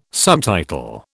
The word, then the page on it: subtitle: British English